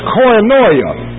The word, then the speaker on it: koinonia: There's a word found a number of times in the New Testament that's pronounced koinonia.